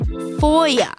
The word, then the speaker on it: FOIA: FOIA stands for Freedom of Information Act and is pronounced FOIA.